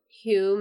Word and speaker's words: Hu: Anyway, I do want to say that right now I'm obsessed with the mint chocolate, and the brand is called Hue Mint Chocolate. I hope that's the way you pronounce it. – No, I think so. It's H-U. – Yeah. H-U. Hue Mint Chocolate.